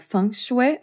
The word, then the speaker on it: feng shui: I didn't read much about feng shui.